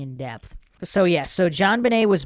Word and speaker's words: JonBenet: Other facts, JonBenet was named after her father, John Bennett Ramsey, and her name is pronounced in a French style, JonBenet.